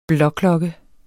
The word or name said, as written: blåklokke